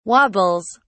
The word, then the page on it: wobbles: British English